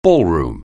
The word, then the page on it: ballroom: anglais